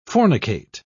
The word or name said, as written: fornicate